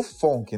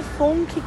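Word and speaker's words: FOMC: Quem é FONC com essa pronúncia? É, gente, eu tenho certeza que eles não pronunciam assim lá nos Estados Unidos, mas como a gente está abrasileirando tudo, o FONC é o Comitê de Política Monetária do FED, o Banco Central Norte-Americano.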